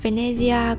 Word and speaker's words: Venezia: Thì thành phố Venice phát âm theo đúng kiểu ý cũng không phải là Venice đâu phải là Venezia